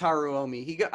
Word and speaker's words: Haruomi: Am I saying that right? Is it Haruomi? – I believe it's Haruomi.